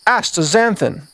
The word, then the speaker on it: astaxanthin: Number one, astaxanthin. Astaxanthin. I'm going to spell it for you. A-S-T-A-X-A-N-T-H-I-N. Astaxanthin.